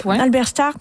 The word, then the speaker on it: Halberstadt: Ce petit orgue en Allemagne, dans une église médiévale à Halberstadt, j'espère que je le prononce bien. – Halberstadt, qui depuis 25 ans joue la même pièce du compositeur John Cage.